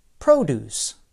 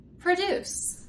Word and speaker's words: produce: For example, the noun produce. The stress is on the first syllable, produce. – But the verb form is produce. The stress is on the second syllable, produce.